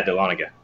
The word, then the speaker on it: Dahlonega: I'm here with two very special guests, Thomas and Robbie from CrossFit Dahlonega. Did I say that right? Did I mess it up? Yes. – Yeah, Dahlonega.